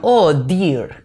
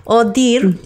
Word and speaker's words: Ohh Deer: En el punto número dos tengo la marca que se llama Oh Dear, que es, mm, por ejemplo, de esta casa, ¿vale? Que estoy enseñando por YouTube, claro. Odir, que se escribe O-H-H-D-E-E-R,